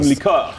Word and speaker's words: liqueur: You can't just say liquor when you gotta pronounce all. – Cream liqueur. – It's liqueur.